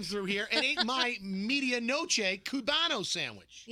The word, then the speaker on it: medianoche: I had the media noche Cubano. And he ate mine. – Yeah. – No, you have mispronounced like 10 words in the past 10 minutes. – It's medianoche Cubano sandwich.